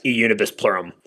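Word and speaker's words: E Unibus Pluram: it's E Unum Pluribus? Instead of Unum Pluribus, correct. – How do you say that? – Correct. E Unibus Plurum.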